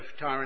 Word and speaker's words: Tyre: I think they pronounce that Tyr these days, but we'll stick to the Tyre, won't we? Tyre and Sidon, as we've always known it.